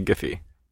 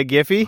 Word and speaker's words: Giphy: We say Giphy. – You say Giphy? – Hard G. – Hard G. – Yeah, Obama says hard G.